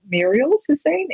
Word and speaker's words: Murielle Sassine: You can follow me on Instagram, and it's my name, which is spelled Murielle Sassine, M-U-R-I-E-L-L-E Sassine.